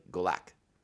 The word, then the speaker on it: Galak: I said it initially with like that hard K, like it was kind of Klingon-y, and it's not. It's like Galak.